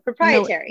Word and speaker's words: proprietary: Because when you solve a problem, your business becomes, you know, proprietary. Sorry, I always say it wrong, but proprietary.